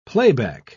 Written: playback

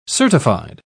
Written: certified

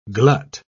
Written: glut

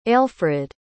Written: ailfrid

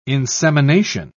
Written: insemination

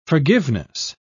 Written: forgiveness

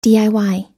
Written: diy

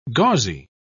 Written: gauzy

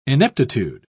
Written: ineptitude

